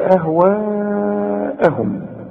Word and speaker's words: أهواءهم: ولئن اتبعت، شوف برضو كل الكلمة دي مرققة وفيها سهولة في النطق، ولئن اتبعت أهواءهم، أهو ده المد الواجب اللي لازم تمده عند الوقف وعند الوصل أربع حركات، ما تقولش ولئن اتبعت أهواءهم بقى، لا، ولئن اتبعت أهواءهم،